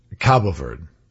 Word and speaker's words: Cabo Verde: I'm seeing, by the way, it's pronounced both ways. It's pronounced Cape Verde or Cape Verde, but the Portuguese pronunciation is Cabo Verde.